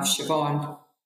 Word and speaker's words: Siobhan: And then they put a fada, which is like a line over the A to make it sound more like ah. So then you have Siobhan.